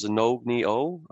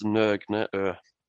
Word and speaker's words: Nogne O: The most common in English is Nogne O, but it's Nogne O.